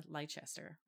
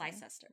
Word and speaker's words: Leicester: Um, after a young woman was found sexually assaulted and murdered in a small uh, town in Le- Leicester, right? That's how you pronounce Leicester. it. Leicester.